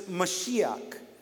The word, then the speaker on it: Mashiach: Now, Messiah actually comes from a Hebrew word that's the way that it's pronounced, it's Mashiach.